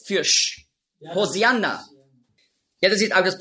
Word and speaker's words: Hosianna: Hosianna ist einfach die griechische Aussprache von Hoshi Anna. Hosianna, das ist die griechische Aussprache. Weil die Griechen, die haben im Alphabet kein Sch-Laut. Nur Z. Die Hebräer schon. Auf Hebräisch sagen Hoshi Anna. Aber Griechen können das nicht.